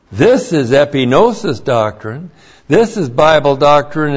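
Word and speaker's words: epignosis: And that is epinosis. E-P-I-G-N long O-S-I-S.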